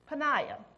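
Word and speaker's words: Peninnah: And one of them was named this Peninah. And it does come from the name Penny. Peninah. Well, that's pretty easy to pronounce. Peninah. It don't look like it's pronounced that way.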